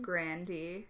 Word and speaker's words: Grande: I hate that her last name is pronounced Grande. – I know. It's not what I was expecting.